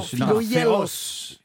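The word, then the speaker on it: Philogelos: Je suis Philoyelos. – Non, Philoyelos. Philoyelos. – Philoyelos. – Ça s'écrit Gelos, mais ça se dit Yelos.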